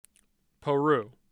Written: peru

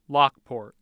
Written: Lockport

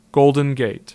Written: Golden Gate

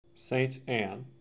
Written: St. Anne

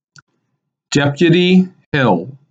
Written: Deputy Hill

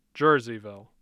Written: Jerseyville

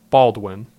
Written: Baldwin

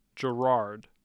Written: Girard

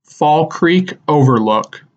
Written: Fall Creek Overlook

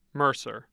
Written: Mercer

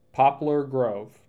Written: Poplar Grove

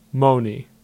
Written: Monee

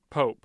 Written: Pope

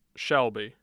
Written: Shelby

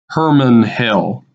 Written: Herman Hill

